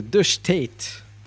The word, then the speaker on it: De Staat: Euh, moi, je vais vous parlais de The State, euh, qui, est, euh, qui est un groupe des Pays-Bas. Donc, ça s'appelle, ça, s'écrit D-E-S-T-A-A-T, The State, qui veut dire l'État, ou de, The State, en fait.